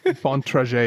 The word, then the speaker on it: Bontrager: Bontrager is the correct pronunciation.